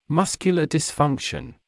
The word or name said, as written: muscular dysfunction